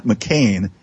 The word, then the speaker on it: McKain: It's just Scott McCain. And the last name is spelled a little differently than that Arizona senator. My last name is spelled M-C-K-A-I-N.